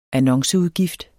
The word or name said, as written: annonceudgift